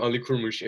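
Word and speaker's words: Ali Kurmuş: I'm Ali Kurmush. If it's really confusing, the name, it's pronounced A-L-I-K-U-R-M-U-S.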